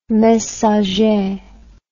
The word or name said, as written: messager